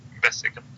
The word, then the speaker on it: Besseggen: It's kind of like big channels of mountain with pure blue water, and everything is just, it's really nice we went to Besseggen. Is that how you pronounce it? – Besseggen, yes.